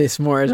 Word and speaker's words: s'more: S'more. Yeah, you put a little space in there.